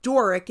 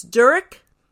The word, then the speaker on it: Durik: It's not Doric, it's DURIK? – Perfect, yes.